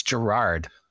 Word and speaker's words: Gerard: It's Gerard, like Gerard yeah. Butler. Oh, a lead singer of My Chemical Romance? No. Oh, that's Gerard Way.